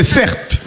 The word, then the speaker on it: Certe: Mon prénom, c'est Certe. Ça s'écrit C-E-R-T-E.